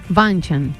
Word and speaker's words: Bündchen: Claro, ¿cómo se dice? – Bündchen. – Ah, mira, yo le dije Bündchen toda la vida. – Como un brochet.